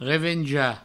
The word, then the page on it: revenjar: revenja; Provençal